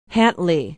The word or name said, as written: hatley